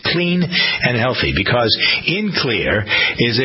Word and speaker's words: Xlear: So I introduced to you something called Clear. X-L-E-A-R, pronounced clear, but spelled with an X.